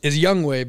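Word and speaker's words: Younghoe: Yep, the kicker for Atlanta, and his name is Young Wei, but it's spelled out like Young Ho.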